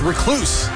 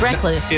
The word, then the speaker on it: recluse: It's recluse. – It's recluse. – It's not recluse. That is weird. You're adding a syllable. You have the emphasis on the wrong syllable.